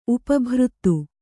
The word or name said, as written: ಉಪಭೃತ್ತು